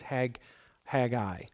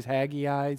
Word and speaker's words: Haggai: I've always pronounced it Haggai, but I think the correct pronunciation is Haggai.